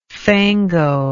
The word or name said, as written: fango